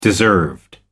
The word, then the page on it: deserved: American English